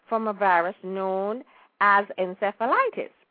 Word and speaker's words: encephalitis: It's E-Q-U-I-N-E. – Oh, yes, equine. Sure. – Uh-huh, and I think it's encephalitis, E-N-C-E-P-H-A-L-I-T-I-S, yes, encephalitis. – Spell it again. – E-N-C-E-P-H-A-L-I-T-I-S.